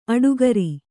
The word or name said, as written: ಅಡಿಗಾರ